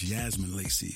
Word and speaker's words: Yasmin Lacey: Like the first track, 90 Degrees, Jasmine Lacey. I'm probably saying that wrong. I think it's Yasmin Lacey from East London.